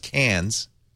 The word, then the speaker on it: Cairns: But I was told several times, no, it's pronounced Cairns and Mackay. And then I realized, that's just an Australian accent.